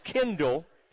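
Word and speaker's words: Chendall: It's C-H-E-N-D-A-L-L, Kendall. It's pronounced Kendall.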